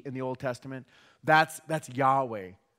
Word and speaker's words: Yahweh: In Hebrew, that would be pronounced either Yahweh, possibly Jehovah, but probably Yahweh.